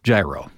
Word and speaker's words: gyro: So in this case, it would be gyro, right? I mean, you say gyro, I believe, when you're talking about something like this, like gyroscope, a gyro plane. Yeah. Okay. But in the food sense, when it's like pronounce it gyro, right? – Yeah.